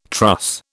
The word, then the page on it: truss: British English